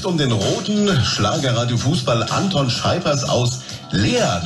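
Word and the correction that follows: Laer: ne? das habe ich richtig ausgesprochen. Ja, La heißt das bei uns. Ah, das ah, ist das La. Westfälische Dehnungsreden.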